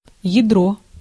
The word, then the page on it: ядро: /jɪˈdro/